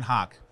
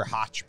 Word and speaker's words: Hoch: You like Hoch or Hotch better? – I get it every single way, and it's never the right way. It is Hoch. Rhymes with Coke.